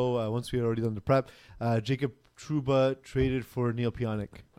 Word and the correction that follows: Pionk: Dude, yeah, it's Pionk, bud.